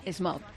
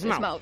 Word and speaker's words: Smaug: No sé, yo oí que era Smaug. – Pues Smaug.